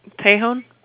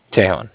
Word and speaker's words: Tejon: Just to double check, you guys are still at 111 South Tejon Street? Or Tejon? – Tejon? – Tejon, okay. Sorry, I'm pronouncing that wrong.